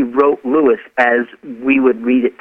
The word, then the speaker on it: Lewes: it is pronounced Lewis, L-E-W-E-S, not lose.